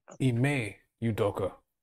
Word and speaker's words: Ime Udoka: Iman Udoka. Ime Udoka.